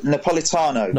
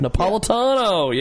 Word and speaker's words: Napolitano: Napolitano? Is that how you pronounce it? – Napolitano. – Napolitano, yeah. Yes.